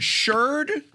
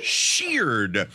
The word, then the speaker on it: Sheard: Is it Sheard, as in herd? – It's Sheared. – Sheared. – As in the past tense of sheer.